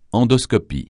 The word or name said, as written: endoscopie